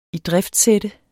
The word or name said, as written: idriftsætte